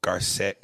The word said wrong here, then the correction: Garcelle: Garcelle Buvo.